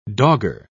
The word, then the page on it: dogger: British English